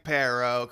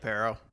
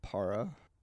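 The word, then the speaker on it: Parro: See, I think in my head I was pronouncing his name Para. – Why? – P-A-R-R-O. I don't know why. – It's fine. – It's like saying Sparrow.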